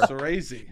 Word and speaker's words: Soresi: Just so you know, it's Soresi, just for the podcast.